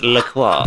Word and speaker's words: LaCroix: Sophisticated folk pronounce it LaCroix.